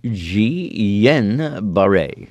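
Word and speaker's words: Guillain-Barré: Okay, and Catherine, the doctor, writes back, hello, friends, it's pronounced Jean-Barre. Jean-Barre.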